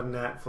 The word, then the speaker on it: gnat: Something I'm just remembering that I thought was funny is when Harry mispronounces something and Lloyd's like, "Harry, you idiot. The G is silent." And then a gnat flies by and he's like, "Oh, stupid gnat."